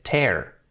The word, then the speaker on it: tear: Tenemos o palabras como por ejemplo near, cerca, que se pronuncia near, y sin embargo tear, que es rascar, es tear. Tear y near.